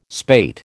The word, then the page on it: spate: American English